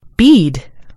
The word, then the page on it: bead: American English